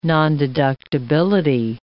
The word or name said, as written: nondeductibility